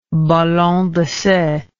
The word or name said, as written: ballon d'essai